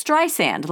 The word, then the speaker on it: Streisand: "My name isn't spelled with a Z," she told him. "It's Streisand, like sand on the beach.